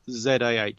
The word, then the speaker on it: Zaher: And the first author of the Spectroveneta paper is Hussam Zaha, or Zaha. And again, I'm surely pronouncing his name incorrectly as well. Now, Zaher.